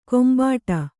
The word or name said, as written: ಗುಂಬಿಡು